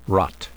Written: rot

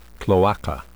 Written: cloaca

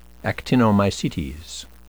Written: actinomycetes

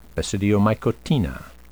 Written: basidiomycotina

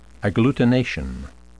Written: agglutination

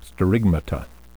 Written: sterigmata

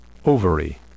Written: ovary

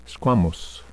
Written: squamous

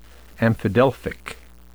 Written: amphidelphic

